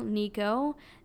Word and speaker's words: Niko: My YouTube channel is at The Real Nico. The Real and then Nico is spelled N-I-K-O.